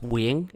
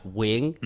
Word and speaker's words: Nguyen: Yeah, it's pronounced Nguyen. It's a Vietnamese surname, one of actually the most popular Vietnamese surnames, actually global surnames. So yeah, Nguyen.